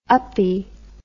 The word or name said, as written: uppie